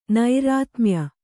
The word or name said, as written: ನೈರಾತ್ಮ್ಯ